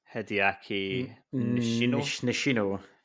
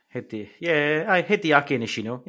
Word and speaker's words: Hideaki Nishino: How do I say his name? – Hideaki Nishino. – Hideaki Nishino, yeah.